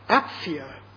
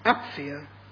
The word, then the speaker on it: Apphia: Philemon, as the word should be pronounced. Apphia is the correct pronunciation of the second word, Apphia, and Archippus.